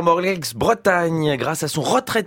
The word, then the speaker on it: Morlaix: Mais oui, les policiers de Morlaix ont reçu une carte postale les remerciant d'une Morlaix, me dit-on dans l'oreille. Attention, évidemment, sûr. – On ne prononce pas de X, ça vaut autant pour moi.